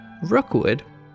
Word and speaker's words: Rockwood: One of these modest plots of land was named Rookwood, which is spelled like Rockwood, but St. Johners won't be very impressed if you don't pronounce it as Rookwood, and that's where the park's name came from.